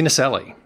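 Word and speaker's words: Naselli: You teamed up with Andrew David Nasselli. Am I saying his name right? – Yes, Andy Nasselli.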